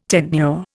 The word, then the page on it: tenure: Indian